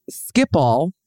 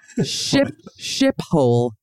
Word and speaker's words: Schiphol: But the airport, which is, I learned today, it's pronounced Schiphol, which means, are you ready? I just found this out. Schiphol. Happy Pride. Happy Pride. It means shithole. Happy Pride. – Shithole? – Ship. It should be shithole. The fact that it rhymes with shithole and it is a shithole airport.